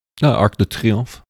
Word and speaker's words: Arc de Triomphe: I had, I saw a photo around New Year's of, I think it was the Eiffel Tower or the Arc de Triomphe. I said that right. – Arc de Triomphe.